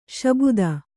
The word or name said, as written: ಶಾಬ್ದ